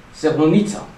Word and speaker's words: Srebrenica: Srebrenica, how do you say it? Srebrenica.